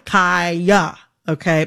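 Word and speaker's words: Khia: I am going to, in this conversation, pronounce Kaya, the rapper, with the long I. Kaya, okay?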